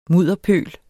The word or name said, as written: mudderpøl